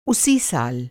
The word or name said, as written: उसी